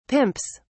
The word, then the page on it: pimps: British English